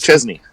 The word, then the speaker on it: Chesney: And then Kenny Chesney right after that, or is it Kenny Chaney? I apologize. – Chesney.